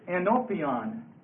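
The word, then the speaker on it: enopion (ἐνώπιον): It's enopion, spelled like this. Enopion. And it's spelled E-N, long O-P-I-O-N.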